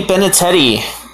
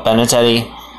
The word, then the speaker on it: Benintendi: Anthony Benintendi, I think I said his last name wrong, but Benintendi, he is now a New York Yankee.